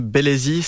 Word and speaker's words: Belezi: L'auteur, c'est M. Bellesi, c'est comme ça que ça se prononce C'est ça.